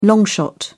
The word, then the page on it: long shot: British English